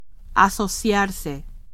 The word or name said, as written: asociarse